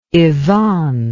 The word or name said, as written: yvonne